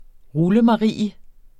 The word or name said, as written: rullemarie